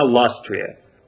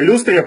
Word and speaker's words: Люстрия: Кстати, как правильно, ластрия или люстрия? – Слушай, ну, если мы смотрим, как она изначально произносится на английском языке, она ластрия. Но у нас уже давным-давно закрепилось произношение люстрия, потому что это смешно и напоминает люстру.